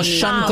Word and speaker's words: Sean: Es como Sean Connery, se escribe Sean.